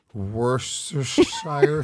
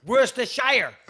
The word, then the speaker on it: Worcestershire: It's not called Worcestershire. It's called Worcestershire. It's called Worcestershire, okay? Sorry, I'm pronouncing it correctly. If I was walking around calling it America, you'd correct me, okay? That's how foolish you sound. It's not Worcestershire. It's Worcestershire.